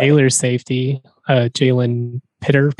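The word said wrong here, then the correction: Pitre: Peter. – Petra. – Can't ever pronounce his name right. Petra.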